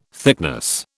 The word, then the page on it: thickness: British English